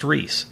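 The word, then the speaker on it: Rhys: That's pronounced Reese. – Yes. – Okay. It's spelled R-Y-H-S, which is not how I would spell Reese.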